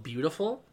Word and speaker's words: Biutiful: What ended up getting in was Javier Bardem for an Iñárritu movie called Beautiful. He got BAFTA only and then the Oscar. But it's spelled B-I-U-T-I-F-U-L.